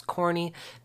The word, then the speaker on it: Cornee: That's Corny, not like a husk of corn. Well, sort of. So it's spelled C-O-R-N-E-E.